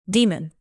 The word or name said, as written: demon